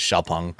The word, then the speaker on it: Xiaopeng: And then I also got to drive the Xiaopeng or Xpeng P7, the proper pronunciation is Xiaopeng.